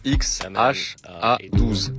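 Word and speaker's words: X Æ A-12: I mean, it's just X, the letter X. The AE is like... pronounced Ash. – Le prénom se prononce X-H-A-12. – A12 is my contribution.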